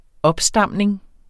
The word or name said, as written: opstamning